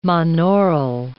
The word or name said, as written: monaural